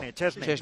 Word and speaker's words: Szczęsny: Compañero del Sport, que hoy ha estado con Szczęsny. ¿Cómo se dice? – El polaco. Szczęsny. Te lo tienes que imaginar como si fuera CH. Szczęsny. Y acabado en Y. – Szczęsny.